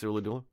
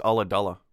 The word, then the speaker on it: Ulladulla: It's that YouTube, here's how to pronounce Ooladoola. I was wrong. It's Ulladulla.